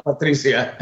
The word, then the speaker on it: Patricia: If you want to say the Spanish style, Patricia.